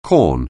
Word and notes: corn: anglais

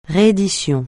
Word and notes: réédition: francais

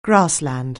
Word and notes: grassland: anglais

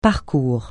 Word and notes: parcours: francais

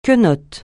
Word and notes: quenotte: francais

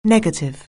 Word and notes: negative: anglais

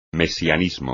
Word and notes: mesianismo: Spanish

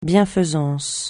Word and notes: bienfaisance: francais

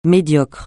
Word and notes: médiocre: francais

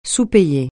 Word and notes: sous-payer: francais

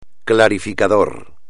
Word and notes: clarificador: espagnol

